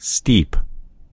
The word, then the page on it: steep: American English